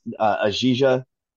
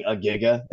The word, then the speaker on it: Ajija: And it's Ajija, which I was always pronouncing Agiga, because it looks like it should be pronounced Agiga.